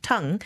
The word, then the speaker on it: Tung: Her surname is spelled T-U-N-G, pronounced tongue, but she has been called Tung, Tang, subject to the mother tongue of the person who's reading her name.